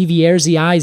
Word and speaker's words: Sieversii: Sieversii. – Sieversii.